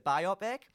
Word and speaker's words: biopic: The matter at hand is whether it's pronounced biopic or biopic. I've since discovered that Viola Davis pronounces it biopic. So if you're listening and you don't think it's biopic, take it up with Viola Davis.